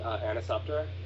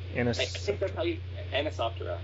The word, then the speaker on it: Anisoptera: How do you pronounce what that's called? – It's called Anisoptera. Anisoptera.